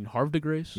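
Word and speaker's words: Havre de Grace: Somebody said to Jackson Holiday last night, hey, you mean Harv DeGrace? – Yeah, Harver. I mean, that's, by the way, if you get that, you feel like that might as well call it pronouncing it correctly at that point.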